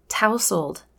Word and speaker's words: tousled: And literally, it says tousled. It even shows the word town, T O W N, to teach how the T O U is pronounced in the word.